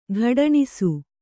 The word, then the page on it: ಗೊಡಣಿಸು: goḍaṇisu; Kannada